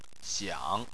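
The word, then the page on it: xiang: tone 3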